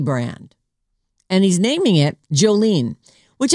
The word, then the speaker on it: Jolene: But it's a latte that's on the lean side, L-E-A-N. But it's spelled Jolene, J-O-L-E-N-E, like Jolene, Jolene, right.